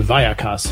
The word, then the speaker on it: Vallecas: the Vallecas haunting. Did I pronounce that correctly? – You did. – Very good. – Check plus.